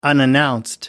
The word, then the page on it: unannounced: American English